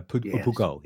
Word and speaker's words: Peugeot: A Peugeot, yeah. – No, it's not a hard G. It's a soft G.